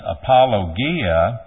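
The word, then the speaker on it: apologia (ἀπολογία): The word in Greek is pronounced apologia. However, when we anglicize it, we change the G, the hard G, to a J, and we change the emphasis as apologia in English.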